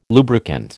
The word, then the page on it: lubricant: American English